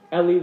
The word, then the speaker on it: Elie: If you've ever heard of or read the book Night by Elie Wiesel or Eli Wiesel, depending on your pronunciation,